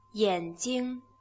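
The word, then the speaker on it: Yanjing (眼睛): Hay que tener mucho cuidado porque es una pronunciación muy parecida a la de gafas. ¿Cómo se dice? – Yanjing. Yanjing. – Como veis, el último carácter tiene prácticamente la misma pronunciación, solo que en gafas pasa a ser un cuarto tono. En ojos es un primer tono.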